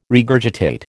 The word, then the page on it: regurgitate: American English